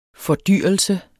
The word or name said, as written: fordyrelse